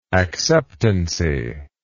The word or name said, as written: acceptancy